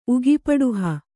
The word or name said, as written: ಉಗಿಪಡುಹ